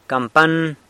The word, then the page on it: campan: San Lucas Quiaviní